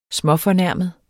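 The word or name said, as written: SMÅFORNÆRMET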